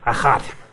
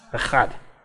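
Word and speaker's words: Echad (אחד): Shema Vayisrael Adonai Eloheinu Adonai Echad. You got to say it like that. Echad. Echad.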